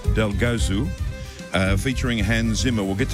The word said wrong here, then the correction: Delgozu: It's actually Delgozu, like like Jesus, yeah.